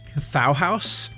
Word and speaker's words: Kathauhaus: The first act, I think it's pronounced Kathauhaus, and it rhymes with Bauhaus.